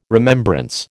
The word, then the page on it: remembrance: American English